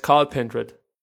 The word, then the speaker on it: Cathal: In der ersten Runde sah es danach aus, dass Cathal Pendred... – Nein, der heißt Carl Pendred. – Wie? – Das spricht man Carl Pendred aus.